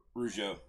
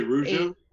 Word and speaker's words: Rougeau: A one-time Johnny Rigueo Tag Team Champion. – Rougeau. – We're going to go with that. Okay, or that. – Did you say Rougeau? – Yeah, spell it out for us.